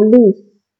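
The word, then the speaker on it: lis: 我再一次，这个词读 list，这个是我们前面后面练习中的这个单词啊，书上给你标音标的。这个词尾的 s 要发音，list 百合花，list 这个 s 要发音。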